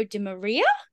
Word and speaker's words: Di Maria: Okay, so, fellow podcast hosts from Next Gen Base, Ben Ward and Nico DiMaria. DiMaria? How do I say that name? – Di Maria, like the shit football player.